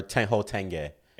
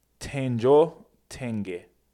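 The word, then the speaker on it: Tenjho Tenge: Yeah, or Tenho Tenge. – And it's actually it's pronounced Tenjo Tenge.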